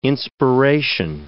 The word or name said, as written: inspiration